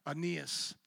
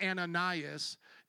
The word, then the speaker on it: Aeneas: Aeneas. It's not Ananias. It's Aeneas. The E there is silent, so it's Aeneas, I believe.